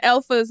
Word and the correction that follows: Efa: name. It's Efa.